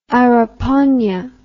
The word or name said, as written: araponga